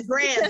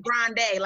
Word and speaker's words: Grande: yeah, Grande, excuse me. It's not the Grande, like the coffee.